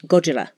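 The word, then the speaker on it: Gojira (ゴジラ): And he actually says the name that they gave it, which is Gojira, which in Japanese pronunciation sounds Gojira. Not the R isn't hard. It sounds more like an L. It's somewhere in between an R and an L.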